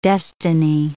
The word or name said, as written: destiny